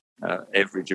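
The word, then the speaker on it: Everage: Everidge, of course, being an Australian pronunciation of the word average, Dame Edna Everidge, who lived down the road here in Moonee Ponds.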